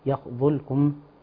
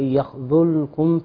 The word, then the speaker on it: يخذلكم: بعض الناس يقول يخذلكم، لكن هنا وقع حرف الذال. بعض الناس يقول بنطقه ضاء، ولكن الصحيح وإن يخذلكم فمن ذا الذي.